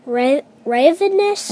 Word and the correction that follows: Rahonavis: Rahonavis. – Rahonavis. Velociraptor. – No, Microraptor. – Microraptor. And Archie is A-R-C-H-A-E.